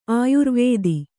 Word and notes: ಆಯುರ್ವೇದಿ: āyurvēdi

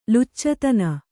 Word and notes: ಲುಚ್ಚತನ: Kannada

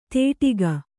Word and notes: ತೇಟಿಗ: Kannada

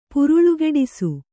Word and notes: ಪುರುಳುಗೆಡಿಸು: puruḷugeḍisu

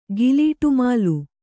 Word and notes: ಗಿಲೀಟುಮಾಲು: kannada

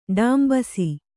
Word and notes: ಡೊಂಬಿಸು: kannada

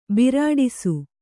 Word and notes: ಬಿರಾಡಿಸು: Kannada

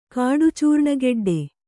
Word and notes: ಕಾಡುಚೂರ್ಣಗೆಡ್ಡೆ: kāḍu cūrṇageḍḍe; kannada

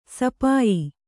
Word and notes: ಸಿಪಾಯಿ: sipāyi

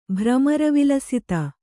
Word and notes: ಭ್ರಮರವಿಲಸಿತ: bhramara vilasita

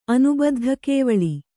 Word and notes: ಅನುಬದ್ಧಕೇವಲಿ: anubaddhakēvali

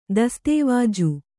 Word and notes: ದಸ್ತಾವೇಜು: dastāvēju

